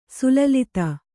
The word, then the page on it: ಸಲ್ಲಲಿತ: kannada